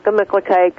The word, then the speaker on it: Gemütlichkeit: Different people put the pronunciation in different places, but Gemütlichkeit, Gemütlichkeit. – Yeah. The Germans actually have the proper pronunciation, though, don't they? Don't they? – And what is it? – Me, Gemütlichkeit. Gemi, me, Gemütlichkeit.